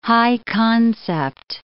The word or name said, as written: high-concept